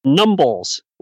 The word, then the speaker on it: numbles: K-I-C-K-S-H-A-W. At least that's the way it sounds in my dialect. Numbles, like mumbles, but with an N at the beginning. – Mm-hmm. – And Spence, S-P-E-N-C-E.